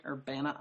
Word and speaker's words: Urbana: Urbana, Iowa? – Perfect, so, yes.